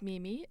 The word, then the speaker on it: Mimi: My name is Mimi. It's pronounced M-I-M-I.